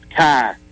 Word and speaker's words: Chi: The 22nd letter of the Greek alphabet is X, pronounced Chi, C-H-I.